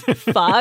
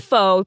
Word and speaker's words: pho: Well, especially ones that, you know, imply that pho rhymes with show, or that it's pho. Yeah. – Oh, yeah. That is my biggest pet peeve. It's pho. It's not pho.